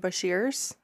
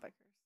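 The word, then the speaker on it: bichir: Funny story, when we first started getting into aquariums, Ryan and I called them Bashirs, but they're actually called Beckers.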